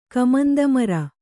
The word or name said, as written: ಕಮಂದಮರ